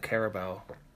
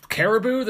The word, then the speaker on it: Carabao: But it's pronounced in a way that makes it sound like you're missaying the word Caribou. Carabao.